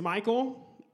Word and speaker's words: Mycal: My name is Michael. My mom spelled my name M-Y-C-A-L.